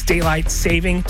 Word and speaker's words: daylight saving: There is no S on daylight saving. It's daylight saving, not savings.